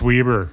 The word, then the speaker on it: Weber: It's Weber. – Weber. – I looked it up. – Did you? Yeah. It seems like it should be Weber, but great. – I knew a guy named Ed Weber, and that's how he spelled it.